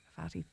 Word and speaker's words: Fatti: And my oldest brother used to call me Fatti because it rhymes with Cathy.